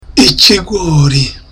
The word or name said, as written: ikigori